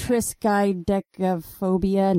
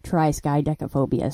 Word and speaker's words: Triskaidekaphobia: I was like Triskaidekaphobia. No, the man on Google said Triskaidekaphobia.